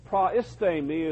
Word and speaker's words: proistemi: Proistemi is the word. It's P-R-O-I-S-T-E-M-I, long A. Proistemi. And proistemi means to be at the head of something, to rule or to manage.